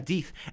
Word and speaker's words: Deeth: does he pronounce it? D, yeah, Deeth. And Paul Deeth, actually originally pronounced death because it's D apostrophe E A T H, yeah, he's the lead my, singer of Mushroom Trail.